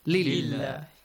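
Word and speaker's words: lillah (لله): Now that you have kasra before the word Allah, you pronounce the word Allah with an a sound. Lillah. Got it? What? Like bismillah. Suppose there is mim. Bismillah. Lilla, filla, billa. Okay? So these are the examples. Thank you. Nice question. So you pronounce it with an A sound because there is a kasra sound.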